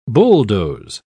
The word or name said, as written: bulldoze